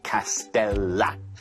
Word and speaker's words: castella: 하지만 솔직히 R 아니야? L이요. 그래서 castella. 발음은 castella.